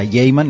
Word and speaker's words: Gaiman: Un señor que tiene un apellido que en realidad se pronuncia Gaiman, le vamos a decir Gaiman y no nos vamos a complicar la vida.